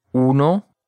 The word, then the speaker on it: uno: Now remember, folks, when you're pronouncing the O in uno and dos, you have to use a Spanish O, right? Uno, dos. Not the American O, right? It's not dos or uno. It's uno and dos.